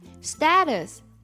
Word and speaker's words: status: Status, 或者读作 status, 指的是状态的意思。